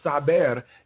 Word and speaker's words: saber: And then the other word was saber, S-A-B-E-R, and we use it like sabes que, you know, do you know, or did you know that, you know, it's to know a fact. So the verb saber